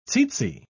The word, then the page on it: tzetze: British English